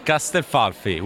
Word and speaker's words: Castelfalfi: It is Castel Falfi, which is in Tuscany, right in the middle between Florence, Pisa, and Siena.